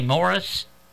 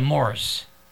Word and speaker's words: Morse: There's a road in northern Columbus, Morse. Have you ever noticed how a lot of people pronounce that? – But they say Morris? – Yeah, they say Morris. – Yeah, instead of Morse.